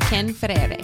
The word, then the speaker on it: Ken Ferreira: Here with Ken Ferreira.